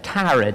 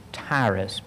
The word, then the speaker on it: Tyre: Now we pronounce that Tyre today. The old King James pronounces it Tyrus, but that is the kingdom of Tyre.